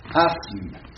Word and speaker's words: asthme: Asthme. – Hein? Donc, il a aussi, n'est-ce pas? S, M, n'est-ce pas? Faisons, écartons le TH de l'orthographe, n'est-ce pas? Qui ne se prononce pas.